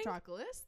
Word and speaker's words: Patroclus: Patroclus, thank you.